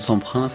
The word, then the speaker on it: Semprun: Nous allons prononcer à la française le nom de Semprin, c'est plus sûr.